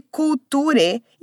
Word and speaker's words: culture: A gente escreve culture e muita gente se bate ali com esse U no começo da palavra porque ele não tem som de U.